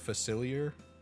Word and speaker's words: Facilier: Dr. Facilitator, I think. – There's no T in there. Doctor, what did I say? Facilitator? – Facilier? – Facilier?